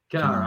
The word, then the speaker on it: Kennard: Kennard.